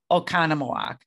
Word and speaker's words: Oconomowoc: So obviously, because we're from Wisconsin, we all know how to pronounce Oconomowoc.